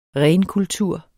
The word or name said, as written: RENEST